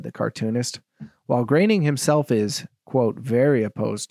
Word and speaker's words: Groening: Matt Groening, did I pronounce that right? – Yeah.